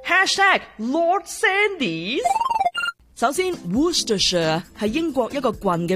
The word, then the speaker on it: Worcestershire: 英式的读法不是隻隻字都读出来的， WORCESTERSHIRE Worcester Worcestershire。其实很多英国地名都保留古老串法的，但是呢发音缩短了，最简单的记法就是整串字母不用读 CES 的，就这样读 Worcester sauce Worcester sauce，大致上明白了。